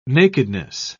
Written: nakedness

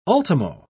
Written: ultimo

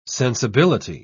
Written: sensibility

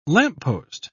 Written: lamppost

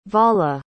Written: vala

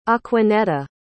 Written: aquanetta

